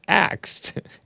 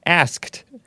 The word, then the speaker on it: asked: she mispronounced axed. Asked. Ask.